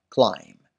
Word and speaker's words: climb: بی تلفظ نمیشه. Climb نه. Climb. Climb.